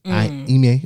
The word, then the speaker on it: Ime: Ime Udoka. – Spell it. – I've been calling him Ume all week.